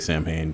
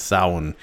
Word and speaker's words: Samhain: I can never pronounce it right, so I say Samhain, but it's not. It's Samhain. But I hope everyone, all the little ones, got a lot of candy and good stuff.